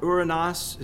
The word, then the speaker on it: Uranus: Heaven, Uranus, or Uranus is probably a more correct pronunciation.